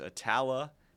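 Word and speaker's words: Attala: He was first elected to the House in 2011 and represents District 48, which is Etala, Carroll, Holmes, and Leake counties. I had to get a pronunciation on Etala before the show. – Nailed it.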